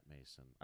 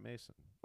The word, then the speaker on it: Maeson: His name is spelled M-A-E-S-O-N. – Yeah, they spelt it like Mason, like Mason jar.